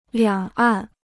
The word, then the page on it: 两岸: liǎng àn